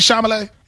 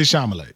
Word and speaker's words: Chalamet: it Timothy Chalamet? – He said Chalamet.